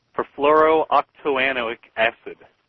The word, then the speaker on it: Perfluorooctanoic acid: can you pronounce it? – Perfluorooctanoic acid.